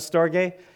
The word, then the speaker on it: storge: And then another one is pronounced storge, at least I pronounce it storge.